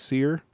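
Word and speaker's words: Cyr: It's C-Y-R, pronounced Seer.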